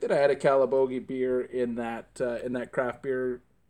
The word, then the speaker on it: Calabogie: All right, we're back, and our next selection tonight for our second beer is, how do you pronounce that? Calabogie? – Calabogie, small town, Calabogie Brewing.